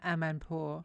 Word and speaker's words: Amanpour: She's, I think she gets presented with her award by Christine, how do you say her surname? – Anna Amanpour. – Anna Amanpour, thank you.